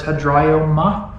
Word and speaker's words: hedraioma: Ground is hedraioma. That's how you say it.